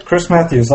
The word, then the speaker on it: Chris Matthieu: Yep, Chris Matthews, like two first names.